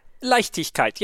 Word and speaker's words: Leichtigkeit: Like tick kite. Easy. Did I pronounce that right? – Leichtigkeit. Yeah, very good. – Leichtigkeit.